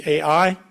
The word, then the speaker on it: Ai: What sin was it? So, they thought everything was good. They went to Ai, to the next city, Ai, pronounced I.